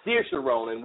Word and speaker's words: Saoirse Ronan: Saoirse Ronan, or Saoirse Ronan, which is the correct pronunciation of her name, is Irish by birth, and this movie feels like a really personal story.